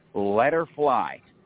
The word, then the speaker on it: Letterfly: The best name to just remember for all of my contact information is Letterfly, and that's spelled L-E-T-T-E-R-F as in Frank, L-Y, Letterfly.